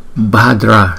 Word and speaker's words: Bhadra: Ở đây chữ Kiên Đức Phật thì chúng ta là Bhadra Shriye. Ba, ba, ba. Chữ B, chữ H chúng ta đọc là B, B.